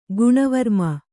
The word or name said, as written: ಗುಣವರ್ಮ